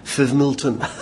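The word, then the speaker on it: Fivemiletown: was told by the locals that the pronunciation of the village of Five Mile Town, which was in the news quite a bit, was Fivmilton.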